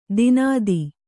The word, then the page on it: ದಿನಾದಿ: dinādi